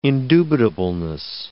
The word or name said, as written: indubitableness